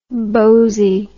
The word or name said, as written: boasie